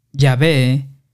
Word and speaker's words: Yahvé: nos inclinamos a ver la pronunciación Yahvé como la forma más correcta, hemos retenido la forma Jehová debido a la familiaridad de la gente con esto desde el siglo XIV.